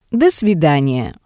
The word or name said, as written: do svidaniya